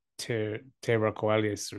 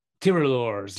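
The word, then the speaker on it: tirailleurs: I have one from Steve Hall correcting our pronunciation of the Tiralores. – Ah. – I think saying Terraqualis or something. – Tiralores. – French light infantry.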